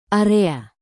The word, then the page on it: area: indonesian